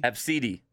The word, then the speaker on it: Abcde: And this person said they came across a kid named A B C D E, and it's pronounced Abcde. – Absidi? – Absidi.